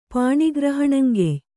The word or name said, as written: ಪಾಣಿಗ್ರಹಣಂಕೆಯ್